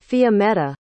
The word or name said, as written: fiammetta